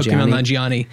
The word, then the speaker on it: Kumail Nanjiani: And I was thinking like, I'm going to butcher his name, Kumail Nanjiani. – Oh, Kumail Nanjiani.